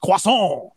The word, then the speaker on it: croissant: I have to pronounce it correctly in the French, croissant.